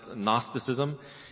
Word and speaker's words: Gnosticism: It begins with a G, but it's pronounced Gnosticism.